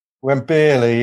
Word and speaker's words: Wembeerly: Wembeerly, yeah. Wembley with beer in the middle.